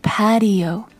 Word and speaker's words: patio: P-A-T-I-O Patio Patio 因为它的 spelling 它的拼写是 P-A-T-I-O 另外一个跟这个单词很相像的是 Ratio R-A-T-I-O 不同的字母开始一个是 P 一个是 R 但是后面的 A-T-I-O 都是一样的 So Patio 是这样的读音 Patio Ratio 是 RatioRatio 就是比例， So very different words and very different sounds, but very similar spellings.